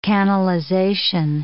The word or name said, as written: canalization